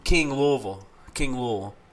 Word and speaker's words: Louisville: And if you say it correctly, it's Louisville, so King Louis is who that's named after, but it's really Louisville.